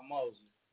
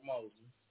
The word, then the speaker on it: Mosley: Rob Mosley. Robert Mosley.